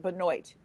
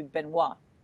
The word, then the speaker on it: Benoit: She pronounces it Benoit, and if you're Canadian, it would be Benoit.